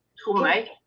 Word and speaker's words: Tromøy: Greg has mentioned that you live close to where Henry Lawson's father and grandfather lived in Norway at, I think I'm pronouncing it right, Tromøy? – Tromøy. – Tromøy. Okay. Tromøy.